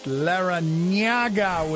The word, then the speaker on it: Larrañaga: He wants to be, have his name pronounced Larrañaga with an N.